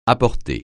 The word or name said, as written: apporter